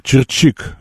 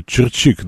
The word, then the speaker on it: Чирчик: Григорий из ПБ меня поправляет. Тут ударение Чирчик, видимо. Чирчик, да, и медведь тоже.